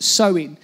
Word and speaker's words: Samhain: The root of Halloween was from a Celtic festival called Samhain, and it's spelled Samhain, it's pronounced Samhain.